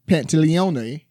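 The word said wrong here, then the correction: Pantoliano: Pretty sure I'm mispronouncing it. It's Captain Pantaleone. Pantaleano. – Okay. – Pantaleano. – All right. – Joe Pantaleano. Joe Pantaleano.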